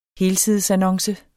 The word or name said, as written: helsidesannonce